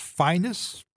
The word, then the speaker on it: Phineas: En het gaat over een plaat van een uit Memphis afkomstige Phineas Newborn Jr. Uit betrouwbare bron hebben wij begrepen dat zijn naam uitgesproken moest worden als Finus.